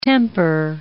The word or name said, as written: temper